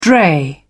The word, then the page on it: dray: British English